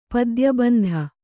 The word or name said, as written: ಪದ್ಯಬಂಧ